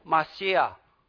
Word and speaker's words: Mashiach: Hebrew word pronounced Mashiach, and in Greek word Christo, which is where we get the English word Christ.